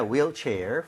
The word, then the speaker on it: wheelchair: 발음할 때 휠이 아니라 뭐라 그랬죠? – Wheel. – wheel, right? 우리가 흰색도 화이트라고 안 하죠? – White. – white이라고 하죠. 그래서 네. – h가 없는 느낌으로 발음할 때 wheel chair이라고 하는 게 맞습니다.